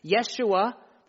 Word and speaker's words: Yeshua: Yeshua, the Hebrew pronunciation of Jesus, means Yahweh saves, or God saves.